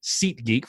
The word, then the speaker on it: SeatGeek: So that's SeatGeek, SeatGeek, for a lot of you that, for whatever reason, don't hear me say that correctly. So it's SeatGeek.